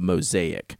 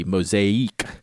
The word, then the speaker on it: Mozaique: M-O-Z-A-I-Q-U-E, Mosaic. And the story goes, so I have one of the original King of Pen mosaics. That's how I pronounce them, just like mosaic, like M-O-S-A-I-C, like a tile table.